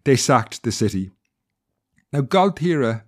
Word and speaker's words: Gaultier: And if you travel from Dunmore East to Waterford City, you pass through an area called Galtiera. Now, this is almost French sounding, and when you see it written down, it's spelled G-A-U-L-T-I-E-R, you'll be forgiven for thinking it was perhaps French in origin.